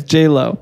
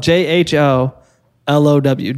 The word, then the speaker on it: Jho Low: It's J Lo. – No, okay, cool. – No, Jho Low. It's spelled J H O L O W. Jho Low. Right.